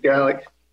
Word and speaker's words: Gaelic: or was probably better known as Gaelic, but we pronounce it Gaelic here, because a lot of that language is still, it doesn't really have a lot of terms for domination either.